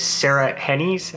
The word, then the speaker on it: Sarah Hennies: So the album was co-produced with Sarah Hennies.